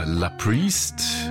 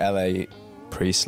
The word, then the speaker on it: LA Priest: Again, it's just like LA Priest, La Priest. There's not exactly a right way to say it.